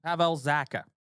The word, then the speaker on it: Pavel Zacha: Pavel Zacha.